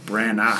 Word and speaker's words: Branagh: It ends with a G-H, which is weird, but it's Branagh. – Well, A-G-H is like, ah. – Ah. Branagh.